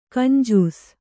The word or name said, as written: ಗುಂಜಿಸು